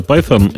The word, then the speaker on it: Python: Э, я тут заинтересовался все-таки, как же правильно произносится слово Python, и оказалось, что я произношу его правильно, там, правда, TH.